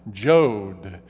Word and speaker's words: Yod: Y-O-D. Some people pronounce it with a J sound, Jod.